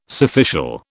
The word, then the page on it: surficial: British English